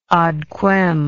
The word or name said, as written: ad quem